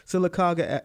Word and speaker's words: Sylacauga: Sylacauga, Alabama.